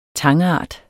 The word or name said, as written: tangart